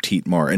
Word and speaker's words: Thietmar: It is spelled uh, T H I E T M E R, but I, I, think it would be Teetmar, sort of Dietmar.